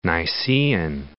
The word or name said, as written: Nicaean